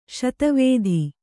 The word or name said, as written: ಶೀತವಾತ